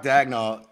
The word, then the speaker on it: Daigneault: It's Mark Daigneault, and he's he's from Leominster, Massachusetts, which is not too far from where I grew up.